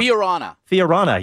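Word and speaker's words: Fiorano: Hey, also, Ferrari's going to run the 2018 F1 car at, how do you pronounce it, Fiorana? I always mess that up. – Fiorana, yeah, in Italy, one of those Ferrari tracks.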